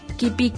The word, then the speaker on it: Kipi: Busquen como Kipi, Kipi K-I-P-I y acento en la I.